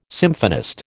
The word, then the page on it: symphonist: American English